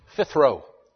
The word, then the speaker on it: phtheiro: The word corrupted is the word P-H-T-H-E-I-R-O, pronounced phthiro. P-H-T-H-E-I-R-O.